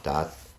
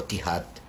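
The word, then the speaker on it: THAAD: O TIHAT, o sea, hay distintas formas de pronunciarlo. Me estoy acordando ahora del TIHAT, pero TAD le dicen los árabes, porque tienen un desarrollo similar a ese.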